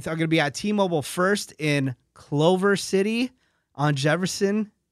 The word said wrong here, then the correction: Culver: Did I say that right? – Nope. Culver.